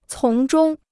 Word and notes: 从中: cóng zhōng

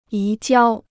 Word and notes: 移交: yí jiāo

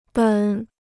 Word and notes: 本: běn

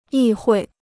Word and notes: 议会: yì huì

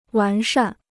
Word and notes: 完善: wán shàn

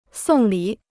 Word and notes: 送礼: sòng lǐ

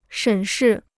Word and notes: 审视: shěn shì

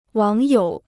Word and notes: 网友: wǎng yǒu

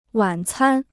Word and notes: 晚餐: wǎn cān

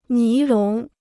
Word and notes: 尼龙: ní lóng

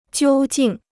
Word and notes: 究竟: jiū jìng

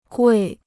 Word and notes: 贵: guì

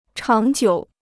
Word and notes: 长久: cháng jiǔ

